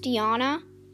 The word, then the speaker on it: Jana: It's not spelled how it sounds. It's spelled like Jana, but pronounced Yana.